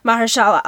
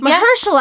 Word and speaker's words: Mahershala: So in December 14th, 2018, Mahershala Ali, am I saying his name right? – Mahershala. – I said Mahershala. It's wrong. The inflection is wrong.